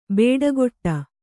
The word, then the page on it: ಬೇಡಗೊಟ್ಟ: bēḍagoṭṭa